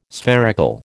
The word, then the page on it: spherical: American English